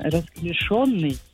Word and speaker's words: расклешённый: Клёши – это слово неглагольное. – Так. – Расклешённый.